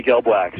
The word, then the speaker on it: Gelbwax: Was that Jeremy, I can't pronounce his name, Gelbwax? Is that right? – Yeah, Jeremy Gelbwax, yeah.